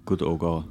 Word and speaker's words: Gut Oggau: Gut Oggau. Do I say that correctly? Gut Oggau. – Gotogo. – Good.